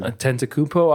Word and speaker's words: Antetokounmpo: Antetokounmpo. I usually just ignore that first N. And kind of go from there.